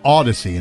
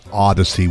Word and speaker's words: Audacy: But it's Odyssey, and it's spelled A-U-D-A-C-Y. It's not Odyssey with an O.